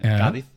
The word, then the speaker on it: Cádiz: Het zal niet Castellaans zijn, maar Andalusisch. Hè? Dus Cádiz. El Cádiz. Cádiz, zeggen we dan als het wat meer beleefd moet.